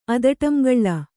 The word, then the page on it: ಅದಟಂಗಳ್ಳ: Kannada